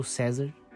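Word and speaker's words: CESRV: Então, meu último realmente vai ser um EP do César. Que se escreve é C-E-S-R-V, o famoso César,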